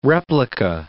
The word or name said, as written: replica